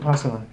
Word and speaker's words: Vipassana: Vipassana? How do you pronounce it? – Vipassana, correct.